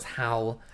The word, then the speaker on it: Halle: How do you pronounce the last name? – No, it's Hal. – Hal. – Hal. I always confuse it with Halle Berry.